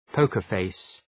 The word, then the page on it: poker face: ‘pəʋkərfeıs